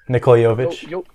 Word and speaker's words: Nikola Jovic: Nikola Jovic.